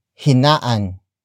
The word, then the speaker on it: hinaan: Hinaan nyo na ang TV. Hinaan nyo na ang TV. The first word is hinaan. Hinaan. Hinaan. Hinaan is spelled as H-I-N-A-A-N.